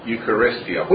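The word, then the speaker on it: Eucharistia (εὐχαριστία): I'm told that they are pronounced roughly diesis, prosuche, intusis, and Eucharistia.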